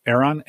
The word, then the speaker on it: Aeron: Aeron? – Aeron, A-E-R-O-N, used Aeron chairs.